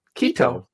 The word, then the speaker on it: Quito: Quito.